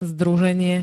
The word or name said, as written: združenie